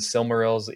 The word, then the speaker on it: Silmaril: I was telling Dan, I think when we last spoke, I think we're pronouncing Silmarils wrong. I think it's, I read like on the Arda Encyclopedia, it's Silmaril. – Are you kidding me? – So, yeah, but I've never heard anyone pronounce it that way.